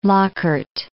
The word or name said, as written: Lockhart